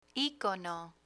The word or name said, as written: icono